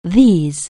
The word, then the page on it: this: anglais